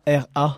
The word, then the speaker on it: Aihara: Comme tu prononces, je vais pas oser, c'est Aihara. – Exactement.